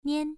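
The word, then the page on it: nian: tone 1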